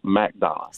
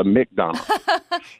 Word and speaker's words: McDonough: Actually, I am born and raised here since 78, and I've always known it as MacDonough. So I get offended when I hear the McDonough. – So you say it like it's M-A-C. MacDonald.